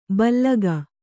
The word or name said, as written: ಭೀಲುಕ